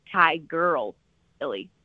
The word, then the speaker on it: Tigirlily: And Tigirlily is spelled with all I's because it's Tigirlily, Tigirlily.